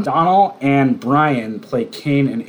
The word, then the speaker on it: Domhnall: Am I saying his first name right? – I think it's Donald. I think it's pronounced Donald. – Donald? Is it? – Yeah, okay. – It's D-O-M-N.